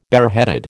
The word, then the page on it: bareheaded: American English